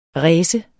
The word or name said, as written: race